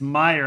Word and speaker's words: Meyer: I called him Scott Mayer when I introduced him, and he was like, nope, it's spelled that way, but it's Meyer. And I was like, I better say it right. It's Scott Meyer.